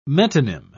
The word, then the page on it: metonym: British English